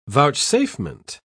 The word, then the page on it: vouchsafement: British English